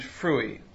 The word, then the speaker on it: frui: For Augustine, there are 2 modes of love, uti and frui. Peter, did I pronounce those right? – Yeah, that was good.